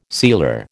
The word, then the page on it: sealer: American English